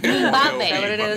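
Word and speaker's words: Mbube: Yeah, and it's, I think it's actually pronounced, it's spelled like M-B-O-B-E, but it's pronounced, it's not. – Mind your own business? – It's like Mbabe. That's how you say it, Mbabe.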